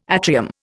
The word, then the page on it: atrium: Indian